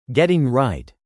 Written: Getting right

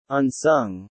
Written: unsung

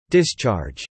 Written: discharge

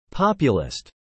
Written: populist

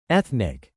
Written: ethnic

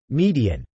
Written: median

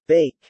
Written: bake

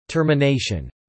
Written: termination

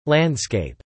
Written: landscape

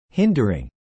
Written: hindering

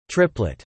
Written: Triplet

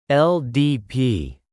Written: LDP

